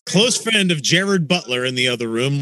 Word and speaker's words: Gerard: Maybe I could be completely wrong, but I recently heard that it's actually pronounced Gerard.